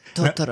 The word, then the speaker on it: Totoro: you are pronouncing that quite incorrectly." And they wanted me to say like Totoro. – Yeah, Totoro.